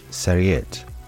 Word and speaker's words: Sarriette: Savory in French is pronounced Sarriette and spelt S-A-R-R-I-E-T-T-E.